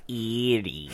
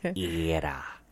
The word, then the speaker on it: Erie: It's not Erie. It's Era.